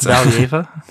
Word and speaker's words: Valieva: So the Russian Anti-Doping Association argued that since the lab in Sweden didn't process the test for 6.5 weeks, Valieva, there's no way I'm pronouncing that right. I pronounced it like it's a Spanish word. – No, it sounds good. Like Valieva.